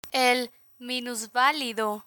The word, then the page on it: el minusvalido: spanish